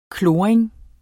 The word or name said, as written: kloring